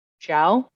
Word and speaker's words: Zhao: It's Shiran J. Shao. Zhao. I believe. – Yeah, Shira J. Zhao.